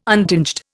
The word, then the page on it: untinged: Indian